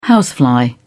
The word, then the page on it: housefly: British English